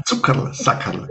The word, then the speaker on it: Zuckerl-Sackerl: Alle Zuhörerinnen aus Österreich mögen mir verzeihen, ein Zuckerl-Sackerl. – Zuckerl-Sackerl, ganz genau richtig. An der Betonung müssen wir noch ein bisschen arbeiten, aber der Begriff ist absolut richtig.